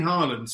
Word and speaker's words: Haaland: And I have no problem with City, and I'm, you know, I mean, Erling Haaland, specifically on him, Haaland, sorry, that is the Nordic way of pronouncing it.